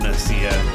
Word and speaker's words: Nicaea: Good job, Nicaea. – I don't know if you're saying it right, but yes.